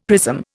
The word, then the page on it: prism: Indian